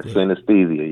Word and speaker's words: synesthesia: It's called synesthesia. – Yeah. – Am I pronouncing it right? – Yeah, synesthesia, yep.